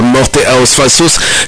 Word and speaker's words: Morte aos Falsos: Il s'agit de Dorsal Atlantica et le morceau qu'on écoute c'est Morte aos Falsos. Est-ce que j'ai bien prononcé? C'est bien ça, Morte aos Falsos, sur l'album de 86, Antes do Film, s'il vous plaît.